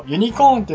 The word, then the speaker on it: UC: はい、 UC をユニコーンって読むなんて普通はわからないから大丈夫大丈夫。